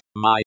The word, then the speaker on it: мы: Pronounced, my, as in, my cat.